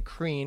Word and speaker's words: Crean: So Jody and a guy named John, I think you can pronounce his last name Crean.